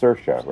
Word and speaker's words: Saoirse: I think it's pronounced Saoirse, right? – Right.